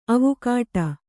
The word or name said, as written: ಅವಘಡ